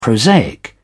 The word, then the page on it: prosaic: British English